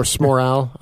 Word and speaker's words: Smorrow: Obviously, it was Matt Smorrow, I'm not going to lie. So Smorrow, I think it rhymes with Moral.